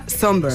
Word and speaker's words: Sombr: Pues mira, yo busqué cómo se pronunciaba por si acaso, pero efectivamente se pronuncia Somber. – Somber, sí. – ¿Y se escribe? – Somber. Sí, exacto. – Y se escribe S-O-M-B-R, por si la gente lo quiere buscar.